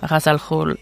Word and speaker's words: Ra's al Ghul: So I would get the head pronunciation, Ras. And then for the G-H-U-L, I used Forvo to find the G-H. And the G-H is a throat sound in Arabic, so it would be Khul. If I ever putfic anything else with Ras, I will from now on say his name Ra's al Ghul, except if corrected, I guess.